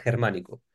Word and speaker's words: Germánico: Alejandro Germánico, the name, is a mix of a usual Spanish name, Alejandro, Alexander, mixed with the Germanicus, the Roman general that in Spanish is pronounced Germánico.